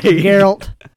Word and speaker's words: Geralt: It's a soft J. – Geralt.